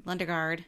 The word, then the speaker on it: Lundegaard: Macy who played Jerry Lengard. – Lundegaard. Yes. Jerry Lundegaard.